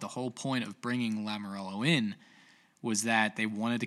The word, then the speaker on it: Lamoriello: I didn't think that would be a move that Lou Lamoriello would make. I don't know how to say it. It's just Lamoriello. Lamoriello.